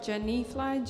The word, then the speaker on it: Jennifly: Jennifly? – Yeah, Jenny, like Jennifer, Jennifly. – Okay. Jennifly. Cool. Yeah, I always say Jennifly, like that short vowel sound.